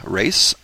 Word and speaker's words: Race: Again, my name is Jim Race, and my last name is spelled just like it sounds.